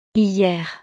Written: hier

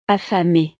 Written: affamée

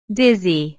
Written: dizzy

